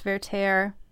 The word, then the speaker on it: Werther: So Werther is a German name, which is why the W is pronounced like a V. – Like a V. – Oh, that's interesting. – So if anyone had any questions about it, it is pronounced Werther.